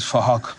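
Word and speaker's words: Phuc: His name is Fahok, but spelt with a P-H, so it sounds like Fahok.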